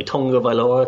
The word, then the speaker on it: Tagovailoa: Tagovailoa, it's supposed to be, but that's not far from the way it's spelled.